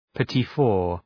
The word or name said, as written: petit four